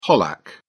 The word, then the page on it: pollack: British English